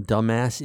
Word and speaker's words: dumbass: And Cammy, who'd been trying to get back at me ever since I pointed out to her that the bee in Dumbass is silent.